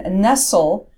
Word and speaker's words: Nestle: But if you mispronounce her name, she gets very angry and she corrects you and says Nestle.